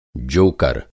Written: जोकर

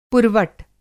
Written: पुरवट

